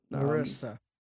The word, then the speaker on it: Marissa: You are finding out what happened to this actress, Marisha Marcel. Marisha Marcel. She did... – Marissa. There's no H.